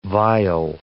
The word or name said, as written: vial